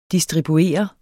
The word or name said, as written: DISTRIBUERENDE